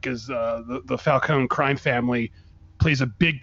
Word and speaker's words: Falcone: John Turturro will play Carmine Falcone, who is the, or I always said Falcone, but in Batman Begins, which the character is also in, they pronounce it Falcone, which I think is weird, but whatever pronunciation you prefer, that's the character he's playing.